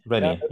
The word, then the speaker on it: Reni: R-E-N-I. – Okay, R-E-N-I. So Roger, England, Nancy, India. – India, correct, yes. – Rennie, okay. – Rennie, yes.